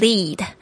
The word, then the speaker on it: lead: It will lead you, 这个 lead, it will, 就是说它会做什么样的事情呢 ?lead, L-E-A-D, lead, 这个字特别注意一下它的 E-A 的发音是这个长的 I 这个声音 ,E,lead, lead you, 就是带领你 to, 到什么地方 ,your purpose, OK, 就是带领你到你的目的。